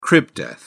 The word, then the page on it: crib death: British English